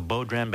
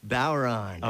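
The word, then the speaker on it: bodhrán: And thank you for teaching me how to pronounce the name of the musical instrument you're playing that I've always called a bodhran, but you call a... – Bowron.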